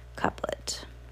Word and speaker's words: couplet: Define couplet. C-O-U-P-L-E-T. Okay, and see how to say it. – Couplet. – Oh, couplet. Okay.